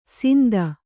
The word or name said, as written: ಸಿಂದ